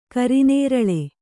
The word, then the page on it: ಕರಿನೇರಳೆ: kari nēraḷe